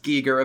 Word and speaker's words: Giger: Giger vibes, and yes, it's pronounced Giger, apparently.